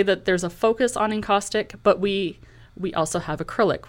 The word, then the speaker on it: encaustic: Encaustic. Encaustic.